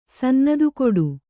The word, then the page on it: ಸನ್ನದುಕೊಡು: Kannada